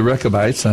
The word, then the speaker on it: Rechabites: Am I pronouncing that correct? – Yeah, the Rechabites.